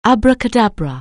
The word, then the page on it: abracadabra: anglais